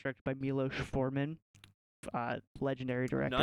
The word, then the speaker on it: Miloš: Yeah, see, I got it. I saw the accent. – You saw there. – The accent there. – Yeah, it's Miloš. I saw it.